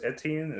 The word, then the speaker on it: Etienne: Because, I mean, a lot of people have us, was it, is it Travis Etienne? Is that how you say his name? – Mm-hmm.